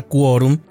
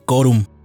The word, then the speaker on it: quórum: Por cierto, hago un pequeño paréntesis para aclarar que la palabra quórum es de origen latino y se debe pronunciar la U, no se debe decir quórum, se debe decir quórum.